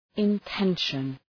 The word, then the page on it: intention: {ın’tenʃən}